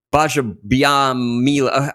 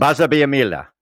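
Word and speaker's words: Gbajabiamila: Anyway, he was on the show with Cheryl Underwood and Amanda Kloots, and now TV presenter and former NFL player Akbar Bajabiamila. The G is silent. – Bajabiamila.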